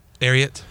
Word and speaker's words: Ariat: Ariat.